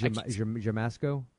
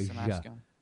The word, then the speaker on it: Siemaszko: Yeah, I mean, they definitely present it as a comedy, because Casey Zemak, how do you pronounce his name? – I think it's Jamasco. I think it's Jamasco. – Samasco? Is it Jamasco? – I think it's Jamasco. I think it makes the SZ makes like a Jamasco.